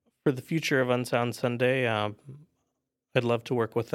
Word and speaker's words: UnSound Sunday: Am I saying that correctly? UnSound Sunday? – UnSound Sunday, yes.